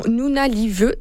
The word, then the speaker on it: Nunavut: Il y a une grosse vidéo aussi qui est surnommée Opération Nunavut. Est-ce que j'ai bien prononcé? Je pense que oui. Hein? – Oui, okay. C'est parfait.